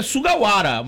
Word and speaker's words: Sugawara: Então, mas é porque no certo é Sugawara, mas aí o cara tá com essa fixação...